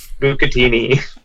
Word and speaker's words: Bucatini: I think you said Bucantini or something like that. – Oh my god. And how'd you pronounce it? – Bucatini.